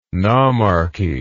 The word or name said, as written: nomarchy